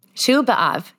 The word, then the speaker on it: Tu B'Av: Anyways, so another thing I just picked up on, you said, so this day, first, I want to make sure I'm saying it right. Tu B'Av? – Tu B'Av, yeah.